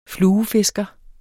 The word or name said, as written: fluefisker